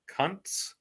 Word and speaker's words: Kuntz: Half the touchdowns went to Ali, the other half went to Zach uh, Kuntz. And yes, I pronounced that the way that it should be, I believe.